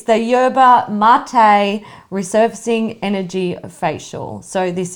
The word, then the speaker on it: Yerba mate: Yeah, it's not phonically. – It's not mate. – Mate. – Yerba mate.